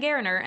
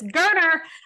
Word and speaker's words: Gerner: You know, it was a bummer specifically for Holden Gerner, which they specified on the broadcast that his last name is pronounced like Turner with a G. And I have been saying Gerner, and I apologize. So it's Gerner.